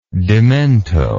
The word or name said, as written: demento